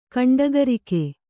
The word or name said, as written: ಗಂಡಗರಿಗೆ